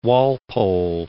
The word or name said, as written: Walpole